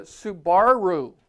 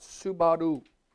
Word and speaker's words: Subaru: Karen grew up in Japan and she'd say, no, you don't put any stress on any syllable. It comes out Subaru. That's the correct way. Say Subaru. Subaru. Not Subaru. Subaru.